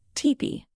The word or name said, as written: teepee